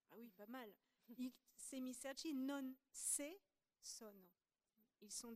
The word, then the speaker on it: semicerchi: Or, les demi-cercles en italien se disent, c'est là que je vais avoir du mal, semicerchi, semicerchi ou cerchi. Mais semicerchi, non, je ne sais pas le prononcer, donc il faut que. – Semicerchi.